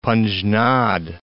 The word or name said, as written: Panjnad